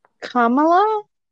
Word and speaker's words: Kamala: Kamala? – Kamala, sorry.